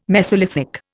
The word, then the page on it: mesolithic: Indian